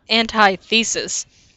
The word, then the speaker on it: antithesis: It's pronounced antithesis, not antithesis.